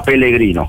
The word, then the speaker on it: Pellegrino: Loro lo pronunciano con una L sola, Pellegrino. – Pellegrino, va benissimo.